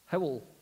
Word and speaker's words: Hywel: I think it's Welsh, H-Y-W-E-L, Howell, Hewell, what? Howell. It's not how it's spelt. They just put a Y in there out of sheer badness.